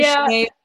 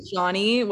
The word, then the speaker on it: Sian: And you can find me on Instagram with @Shanae. Yeah, I know, people never know how to actually pronounce that username. It's either Shanae or Shani, whatever you want to.